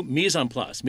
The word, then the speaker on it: mise en place: Whereas cooking students know Mise en Place. Mise en Place is getting your stuff together in one sock before you start cooking.